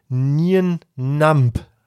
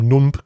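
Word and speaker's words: Nien Nunb: Mit dieser Nien Namp wird er wohl ausgesprochen. Ich habe Nump gesagt am Montag.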